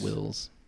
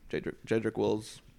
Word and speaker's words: Jedrick Wills: So, besides, like, Ted, the guy who does our strength and conditioning coach, he's, like, he trains people like Jedrick Willis and a bunch of, like, pros. So, he's... – It's Wills. – Jedrick Wills. Sorry.